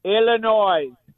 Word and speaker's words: Illinois: Illinois, the S is silent.